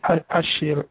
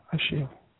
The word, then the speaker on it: Ashil: Ashiel, A-S-H-I-L, Ashiel.